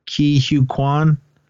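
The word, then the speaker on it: Ke Huy Quan: Oh, I can never pronounce Short Round's name. Ki Hu Kwan. – Yeah, you got it.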